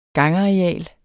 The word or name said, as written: gangareal